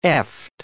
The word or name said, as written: eft